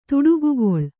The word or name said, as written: ತೀಟಕೋಗಿಲ